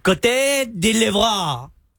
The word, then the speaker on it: Côte d'Ivoire: Côte d'Ivoire.